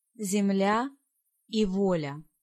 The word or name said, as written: Zemlia